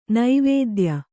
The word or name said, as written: ನವೋದಯ